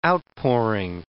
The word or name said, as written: outpouring